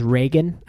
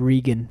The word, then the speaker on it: Reagan: I mispronounced the name. His name was Reagan. I said Regan, but I was once.